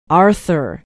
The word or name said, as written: arthur